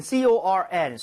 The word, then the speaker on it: corn: 방금 옥수수 할 때는 CORN, so 발음도 corn 이지만, 네.